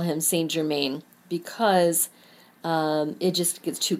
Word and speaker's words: Saint-Germain: If you want to practice forgiveness and transmutation, call on Saint Germain. Saint Germain, well, he's actually not a saint. If you are pronouncing it the French way, it is Saint-Germain.